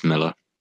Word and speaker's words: Millar: As an Englishman, it is Miller. – Okay. – Even though it's spelled with an A, he's Scottish. – All right, that's fair enough. You're closer. You're closer. We'll give you say. – Yeah, because in Scotland it'd be pronounced Miller as well.